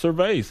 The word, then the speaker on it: Servais: It's Servais. That's how it's spelled. S-E-R-V-A-I-S. That's not service. Nothing service about that. Service is S-E-R-V-I-C-E. Always has been, always will be.